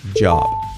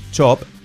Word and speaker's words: job: Escrito J-O-B, job. No es chop, es job, j, j, j, j, j, job.